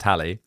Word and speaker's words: Halley: Name is pronounced Halley.